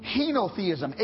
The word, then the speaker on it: henotheism: There is a teaching known as, and I had to learn how to pronounce it, henotheism.